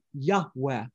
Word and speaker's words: Yahweh: And most Semitic scholars believe that the God of Israel's name is pronounced something similar to a breathy Yahweh.